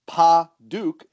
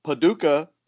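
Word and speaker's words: Padauk: African Paduke is Paduke. It is sometimes mispronounced Paducah, a city in Kentucky.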